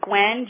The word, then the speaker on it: Guinn: It's Gwen, G-U-I-N-N, and then it's Kaufman, K-A-U-F-M-A-N.